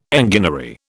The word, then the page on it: enginery: British English